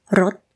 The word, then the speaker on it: รส: So, the word for flavor is รส This word has the same pronunciation as รสwhich means car, but they are written differently. The spellings are different, but the pronunciation is exactly the same.